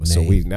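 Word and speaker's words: Saweetie: She beat out Cardi B, Doja Cat, Lizzo, Nicki Minaj, and Sweetie. All my 37 years of age came out with that mispronunciation of that name. – Sweetie. I think you said it right.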